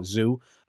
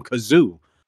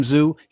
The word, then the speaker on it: Tszyu: Like you said, the three C's, the triple C's, but you don't see them parading, talking about Tszyu. Charlo mispronounces his name all the time, Kazoo.